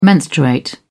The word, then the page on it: menstruate: British English